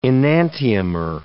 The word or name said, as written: enantiomer